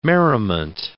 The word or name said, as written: merriment